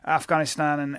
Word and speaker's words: Afghanistan: You know, there was a guy who had traveled all through Afghanistan and everywhere. Notice how I say Afghanistan. That's the Pashtun way of pronouncing it.